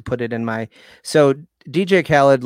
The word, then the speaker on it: DJ Khaled: It's my neighbor has been playing a lot of DJ Khaled. Is that how do you say his name? – DJ Khaled.